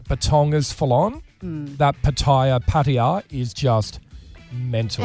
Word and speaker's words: Pattaya: That Pattaya or Pattaya or however you pronounce it. – Mm. Pattaya, yep.